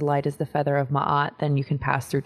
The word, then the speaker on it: Maat: So for my deck, I base it around an Egyptian principle called the 42 ideals of Maat. Some actually pronounce her name Mayat,